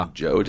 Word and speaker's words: Tom Joad: Tom Joad, is that how you say it? – Yeah.